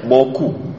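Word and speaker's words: beaucoup: Bau chou, bacanya boku. Merci boku, thank you very much.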